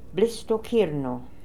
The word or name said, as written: Blissstokirno